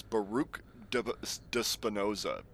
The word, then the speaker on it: Baruch de Spinoza: That was Baruch de Spinoza. B-A-R-U-C-H, and then de Spinoza. Spinoza is spelled how it's spelled, how it sounds.